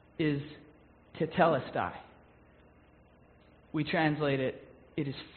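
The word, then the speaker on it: tetelestai (τετέλεσται): The word is pronounced tetelestai.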